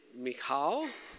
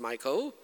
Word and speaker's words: Michal: If I remember correctly, Chris said Michal. I tried to listen. Some of them pronounce it as Michael.